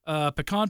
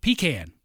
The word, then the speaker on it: pecan: Georgia, pecan pie, or if I say it properly, pecan pie.